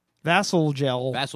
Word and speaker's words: Vasalgel: Yeah, it's spelled V-A-S-A-L-G-E-L. Vassal gel.